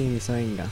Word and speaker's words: MiZoEnGa: Misoenga. Esta vez sí lo dije bien. – Sí, Misoenga.